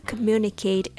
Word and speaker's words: communicate: Today, I'm sharing a tip on how to communicate effectively with the right intonation and rhythm. The word communicate has four syllables come, mune, na, kate.